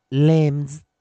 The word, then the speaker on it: limbs: 这个时候注意，它又念成短元音了。所以 i m b m m m limbs limbs limbs。